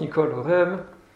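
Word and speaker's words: Nicole Oresme: In French, it's pronounced Nicole Orem. In English, I don't know how you pronounce this.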